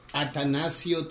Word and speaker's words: Atanacio: Okay, my real name is Atanasio Trigo. And I'm going to spell A-T-A-N-A-C-I-O. Yeah, Trigo, T-R-I-G-O.